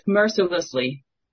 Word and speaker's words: mercilessly: It's pronounced mercilessly. – Yes. – Mercilessly. It's the L's.